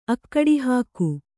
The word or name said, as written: ಅಕ್ಕಡಿಹಾಕು